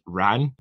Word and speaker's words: wren: Also, before I start, the old Irish pronunciation of wren was ran, WRAN, which is used in these songs.